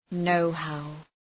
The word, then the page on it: know how: ‘nəʋhaʋ